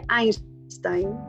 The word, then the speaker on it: Einstein: Visité el Museo de Einstein, Ah, bueno, ahora sé que se pronuncia Einstein.